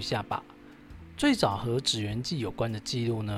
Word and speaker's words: 祇園: 嗯，纸鸢有人认为应该要念作奇缘呢，不过我这边还是按照京都市巴士和金板电车中文广播的念法哦，念作纸鸢呐，请这个奇缘派的人忍耐一下嘿。